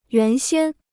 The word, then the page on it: 原先: yuán xiān